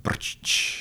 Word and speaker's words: Brčić: Ik denk Birkic. – Nee, dat is niet goed. Je mag nog een poging doen. – Laat hem aan jou. Hoe spreek je achternaam uit? – Mijn naam is Igor Brčić.